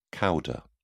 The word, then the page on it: cauda: British English